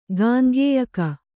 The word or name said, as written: ಗಾಂಗೇಯಕ